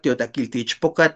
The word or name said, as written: tiotlakilti ixpokatl